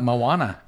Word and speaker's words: Moana: Am I pronouncing it right? Moana? – Moana.